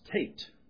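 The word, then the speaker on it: Teth: And this is the part that begins with the ninth letter of the Hebrew alphabet, and it's pronounced Tait, although spelled T-A-T-E-T-H.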